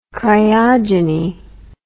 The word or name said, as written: cryogeny